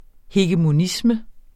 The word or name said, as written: hegemonisme